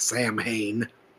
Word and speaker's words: Samhain: or to those of you who don't know how to pronounce things, Samhain.